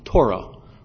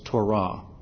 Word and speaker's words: Torah: You may also be familiar with the pronunciation Torah, but we understand the more proper pronunciation is Torah.